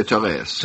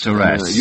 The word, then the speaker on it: Therese: I'm not sure how you pronounce this. Teresa? – I would say Therese. – Therese. – Use a French pronunciation.